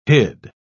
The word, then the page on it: hid: British English